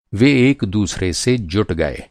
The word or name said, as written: जुटना